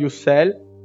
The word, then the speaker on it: Ussel: il castello di Ussel, giusta la pronuncia Roberto, grazie.